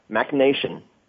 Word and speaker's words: MachNation: MacNation. – MacNation.